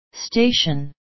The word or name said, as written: station